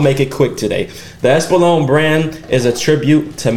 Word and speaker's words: Espolón: The Espolón. I said that right? – Espolón.